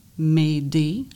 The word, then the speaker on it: mayday: Le mot « m'aider », ça s'écrit « M-A-Y-D-A-Y ».